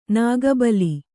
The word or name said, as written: ನಾಗಬಲಿ